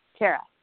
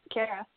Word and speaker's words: Kaira: Kaira. You're right, Kaira. – Okay, and Kaira is spelled K-A-I-R-A, and her last name is spelled R-O-U-D-A.